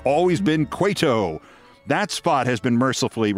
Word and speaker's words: Cueto: saluting Chicago White Sox pitcher Johnny Cuto mispronounced his name like that three times in 30 seconds. Cueto.